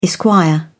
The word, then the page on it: esq: British English